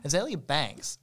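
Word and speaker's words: Azealia Banks: I was just on Twitter, like I always am, and Azalea Banks, is that how you say her name? – Yeah. – Azalea Banks released, like, a single or something.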